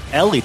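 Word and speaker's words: Eeli: I keep messing that up. Ellie, Eli. Well, I was trying to be funny in the moment, and I actually had studied the pronunciation guide before, just because I always screw it up. So Ellie, it's just Ellie.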